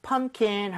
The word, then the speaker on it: pumpkin: 대신에 pumpkin 할 때는 P 발음이 거의 안 된다고 보시면 돼요. 왜냐면은 요 사이에 자음 M하고 K 사이에 sandwich가 돼서 그래요. – 네. – 오케이? 자, 한번 해볼게요. U에 강세를 주고 이런 식으로요.